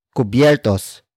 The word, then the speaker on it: kubyertos: Cubiertos. Cubiertos. Cubiertos is spelled as K-U-B-Y-E-R-T-O-S.